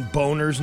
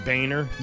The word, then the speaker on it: Boehner: Remember, it was Anthony Weiner who said he was talking about his name and John Boner's name, even though Boner pronounced it Boehner.